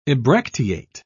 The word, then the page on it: ebracteate: British English